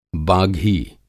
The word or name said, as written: बाघी